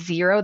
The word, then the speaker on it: Xero: And if you want to level up a little bit, go ahead and use QuickBooks Online, or you can use Xero. That's another one. It's spelled with an X, X-E-R-O.